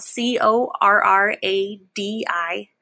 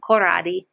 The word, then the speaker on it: Corradi: Um, it's spelled C O R R A D I, Corradi. Uh, just just think of it with an Italian accent, Corradi.